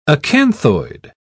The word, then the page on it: acanthoid: British English